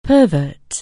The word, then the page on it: pervert: anglais